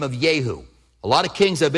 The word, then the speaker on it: Jehu: And we say in English, Jehu, Because we use the J, but Hebrews don't use that. They use the spitting sound. So literally, it would be pronounced Yahu, Yahu. That's how they would pronounce it.